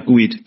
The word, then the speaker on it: GUID: Is it GUID or? – GUID. Yeah.